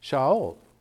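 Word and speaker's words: Shaul (שאול): But remember, that's the Greek form of his Hebrew name, which was Saul, which is pronounced Shaul.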